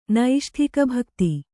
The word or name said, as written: ನೈಷ್ಠಿಕಭಕ್ತಿ